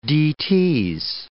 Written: d.t.'s

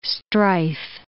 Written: strife

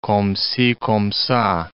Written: comme ci, comme ca

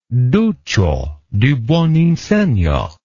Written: duccio di buoninsegna

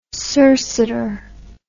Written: circiter

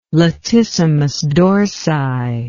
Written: latissimus dorsi

